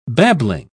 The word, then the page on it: babbling: British English